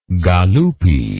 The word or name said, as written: galuppi